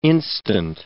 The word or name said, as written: instant